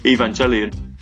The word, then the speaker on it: Evangelion: I always pronounce the go as a G, Evangelion.